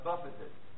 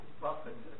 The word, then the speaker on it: buffeted: and buffeted. I always mispronounce that. It's not buffet. It's buffeted. Sometimes I just enjoy my own humor.